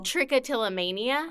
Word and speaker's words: trichotillomania: Trichotillomania.